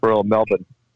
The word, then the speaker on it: Melbourne: Royal Melbourne.